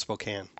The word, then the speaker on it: Spokane: Is it Spokane or Spokane? – I think it's pronounced Spokane.